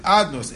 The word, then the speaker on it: Adnus: It's not supposed to be pronounced Havaya. We pronounce it Adnus. It's written Yud, then a Hey, and a Vav, then a Hey. But it's supposed to be pronounced with the Shem Adnus.